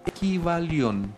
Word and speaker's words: Kybalión: Se llama El Kybalión, se escribe con K-Y-B-A-L-I-O-N, Kybalión.